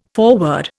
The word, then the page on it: foreword: Indian